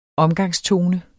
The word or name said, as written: omgangstone